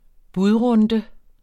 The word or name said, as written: budrunde